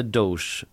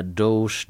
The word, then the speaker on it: Doge: Eh, och eh, sa att jag tror att jag fattar vad du menar, jag är med dig men att jag tyckte att du uttalade Doge fel. – Mm. Den här alltså hunden som är ett meme, känner du till den Amanda? Det Shiba-hunden. – En shiba-hund som är liksom eh, ett meme-format som är ganska stort. Liksom. Eh, men eh, jag trodde att man uttalade dog Mm. – Men det visade sig att det är Doge då.